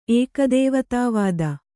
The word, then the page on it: ಏಕದೇವತಾವಾದಿ: ēkadēvatāvādi